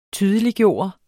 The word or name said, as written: TYDELIGGJORDES